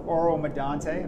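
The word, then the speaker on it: Oro-Medonte: It was a big field in Burles Creek in near Barrie, Ontario, or Oro Medonte. Am I saying that right, Ham? – You got it.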